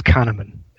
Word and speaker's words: Kahneman: Is that the correct pronunciation, as far as you know? – Yes, he and his mate Amos Tversky were, I think, Israeli psychologists. So as far as I know, it's Kahneman.